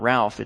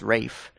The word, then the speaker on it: Ralph: How he got his first name to be pronounced Rafe, I will never know, but it is. It's not Ralph, it's Rafe.